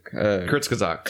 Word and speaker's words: Kurzgesagt: Kurzgesagt. – Kurzgesagt. They have a really good video on it. – Kurzgesagt.